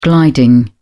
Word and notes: gliding: British English

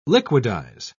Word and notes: liquidise: British English

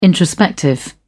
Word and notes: introspective: British English

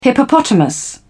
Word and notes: hippopotamus: British English

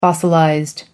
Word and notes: fossilized: American English

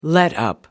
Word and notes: letup: British English

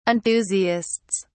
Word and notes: enthusiasts: British English

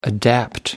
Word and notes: adapt: American English

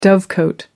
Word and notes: dovecote: American English

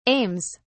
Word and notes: aims: British English

